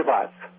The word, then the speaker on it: pazzo: Means you're crazy, and "pazzo" is spelled P-A-Z-Z-O, like pizza, pazzo.